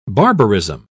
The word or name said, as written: barbarism